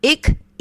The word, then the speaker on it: Ikigai: And it's pronounced Ikigai.